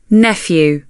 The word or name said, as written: nephew